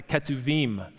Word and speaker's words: Ketuvim: Ruth is one of 5 books that's in a category of books in the Old Testament called the Writings, the Ketuvim, is how you say it in Hebrew.